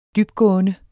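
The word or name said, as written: dybgående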